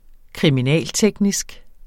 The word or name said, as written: kriminalteknisk